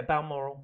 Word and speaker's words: Balmoral: Yeah, Balmoral.